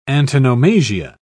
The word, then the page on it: antonomasia: British English